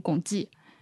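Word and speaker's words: 汞齐: 呃，溶解了之后就会变成汞记，那个字是写作齐，念作记。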